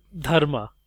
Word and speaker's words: Dharma: Is that Dharma is how we say it, but it's a soft D sound.